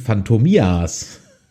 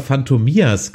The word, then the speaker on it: Phantomias: Ich habe immer Phantomias gesagt. Also Betonung auf die letzte Silbe.